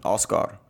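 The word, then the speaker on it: Oskar: Oscar.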